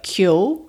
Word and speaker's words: Keogh: Ocha became the root of surnames like Keo, which is spelled K-E-O-G-H, and the surname Haughey, spelt H-A-U-G-H-E-Y.